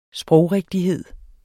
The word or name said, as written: sprogrigtighed